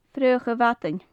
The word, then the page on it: fraoch a bhadain: gaelic